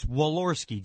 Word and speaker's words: Walorski: Hey, how do you say Jackie's last name? – It's Walorski. Jackie. – That's right. – Walorski.